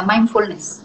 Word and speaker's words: mindfulness: Se pronuncia mind como de mente. – Mente. – fullness, así como se escucha. pero junto, la palabra va junta, mindfulness.